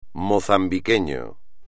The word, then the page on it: mozambiqueño: espagnol